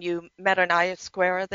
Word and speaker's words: ayahuasca: Is how you pronounce it, ayahuasca.